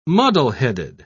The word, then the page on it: muddleheaded: British English